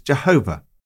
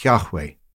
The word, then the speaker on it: Yahweh: Historically, we have often pronounced the word as Jehovah, when in fact it sounds more like Yahweh.